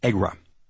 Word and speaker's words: ECGRA: This stands for Erie County Gaming Revenue Authority, and it is usually pronounced as a word, EGRA.